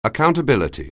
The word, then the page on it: accountability: anglais